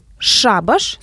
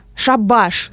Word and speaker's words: шабаш: С одной стороны, шабаш с ударением на первый слог. Это такое сборище ведьм, какой-то нечисти. – Нечисти, да, бесовское веселье. Шабаш с ударением на второй слог. Это, собственно, окончание дела.